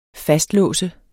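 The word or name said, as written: FASTLÅSTES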